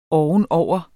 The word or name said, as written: ovenover